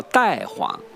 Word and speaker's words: 大黄: 比如说有一个中药叫大黄，原来就叫代黄，它读音呢又可以读个太，跟太也相近，叫太子啊、太君啊，这个呢都表示一种尊重，这个意思。